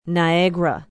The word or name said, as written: Niagara